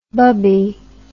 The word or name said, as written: bubbie